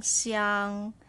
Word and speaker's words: 想: Xi yang xiang xiang xiang xiang xiang. Third tone, we stop here. Xiang xiang so a third tone, you're going down and up, right? So it's quite a deep sound. xiang, down and up.